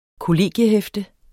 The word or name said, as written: kollegiehæfte